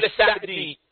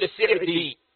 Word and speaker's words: السعدي: وقد سمعت الشيخ رحمه الله في مجالس عدة أحيانا ينطق اسم الشيخ بفتح السين فيقول السعدي، وأحيانا في بعض المجالس ينطقه بكسر السين فيقول السعدي، وهذا يدل على أن الوجهين صحيحان عند الشيخ بن عثيمين رحمه الله.